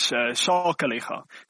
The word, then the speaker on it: Sakeliga: Is it Sakeliga or Sakeliga? Oh, sorry if I called the name wrongly. But it's Sakeliga. You can check the platform. You have done a favor. It means you will take us up to, that will take us up to 208. – Thank you, Chair. Yes, it is Sakeliga. So no worries on the pronunciation there.